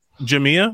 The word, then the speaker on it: Jamia: Jamia. – What? Yeah. – The accent is on the I.